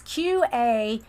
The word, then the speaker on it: QAnon: Is it QAnon or, it's QAnon.